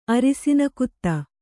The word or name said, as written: ಅರಿಸಿನಕುತ್ತ